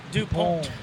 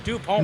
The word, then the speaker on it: DuPont: It's pronounced DuPont. – DuPont. DuPont.